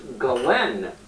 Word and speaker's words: Ghislaine: Apparently it's pronounced Ghislaine Maxwell, spelled Ghislaine.